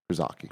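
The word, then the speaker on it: Krzyżacy: That's spelled KRZY. Z with a little like accent on it. It's like a dot on top of the Z. And then A-C-Y. – Krizaki.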